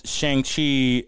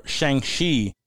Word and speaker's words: Shang-Chi: So apparently the Disney boss came out and called Shang-Chi Shang-Chi, as it's supposed to be pronounced, an interesting experience an interesting experiment, which was taken out of context, in my opinion.